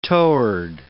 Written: toward

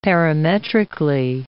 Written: parametrically

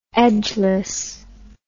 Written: edgeless

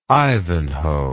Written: Ivanhoe

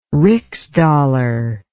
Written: rixdollar

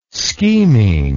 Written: scheming

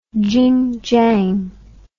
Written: Jinjiang

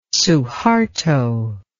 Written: suharto

